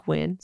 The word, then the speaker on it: Nguyen: So he has a company called the Big Win Company, which is cute because it's spelled like N-G-U-Y-E-N, but it's Big Win.